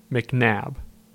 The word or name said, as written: McNabb